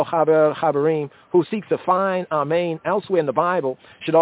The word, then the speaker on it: amen: The accent in both cases is on the second syllable, amen, amen.